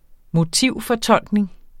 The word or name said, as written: motivfortolkning